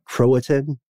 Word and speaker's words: Croatan: It doesn't stop at uh, what Croatan.